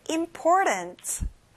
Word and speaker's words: important: 가운데 T 발음은 내도 되고 안 내도 돼요. Important.